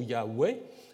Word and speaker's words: Yahweh: la question, c'est plutôt la deuxième syllabe et la disons, la prononciation Yahweh est plutôt attestée en fait par des pères de l'Église, euh, par Clément d'Alexandrie ou Origène également, euh, qui euh, l'attestent.